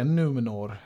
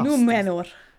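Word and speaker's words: Númenor: de hade en konstig uttal, att få se det i bild och det var ju så fantastiskt. Numenor. – Ja, det var väldigt eller, hårt R.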